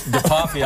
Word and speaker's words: DePoffy: It's DePoffy. I always say it rhymes with coffee, and then everyone remembers it.